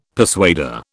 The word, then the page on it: persuader: British English